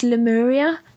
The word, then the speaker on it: Lemuria: and I want to say it's pronounced Lemuria, but I don't know. – That's how I pronounce it.